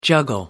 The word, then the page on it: juggle: American English